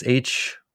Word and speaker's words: hygge: I think it's Hygge, I think. Pronounced hygge. Anyway, it's this idea of, it's like coziness is probably the closest English translation.